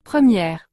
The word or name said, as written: 1re